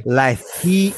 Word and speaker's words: Leith: If you would have seen, I think it was Alex Life. Life? Yeah. I'm probably saying that wrong because of my accent. I'm trying to say L-E-I-T-H, right? Anyway. – Life. – Okay, there you go.